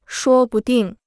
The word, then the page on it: 说不定: shuō bu dìng